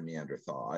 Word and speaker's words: Neanderthal: So yeah, either way is, you know, either thal or tall, you know, that I've heard, or that, you know, everyone's ever told me, is that, you know, it's all in how you want to pronounce it. Pajama, I guess you could say. – I personally prefer Neanderthal. It rings with the pronunciation of the TH and AL.